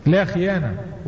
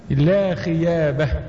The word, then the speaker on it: خيابه: وبعض المحدثين تصحف عليه ذلك فقال لا خيانه، وده تصحيف، والصواب انه لا خيابه،